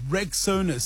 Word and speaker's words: Rexona: Now, if you haven't heard, Shield is now Rexona, a brand you've trusted for years, is stepping forward with a bold new name pronounced Rexona. Simply strong and easy to remember.